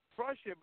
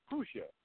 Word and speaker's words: Prussia: And you know, I always pronounce it Prussia, but it's actually Prussia. You have to forgive me for pronouncing it wrong all this time.